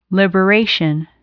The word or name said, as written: liberation